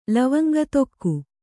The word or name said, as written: ಲವಂಗತೊಕ್ಕು